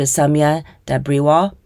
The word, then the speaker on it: Soumya Dabriwal: So without further ado, let me introduce you to Soumya Dabriwal.